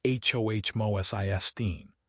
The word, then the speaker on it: homocysteine: By monitoring biomarkers like fasting insulin and homocysteine, pronounced HOH-MoSISTine, and prioritizing metabolic health, one can steer these immune cells toward a protective state.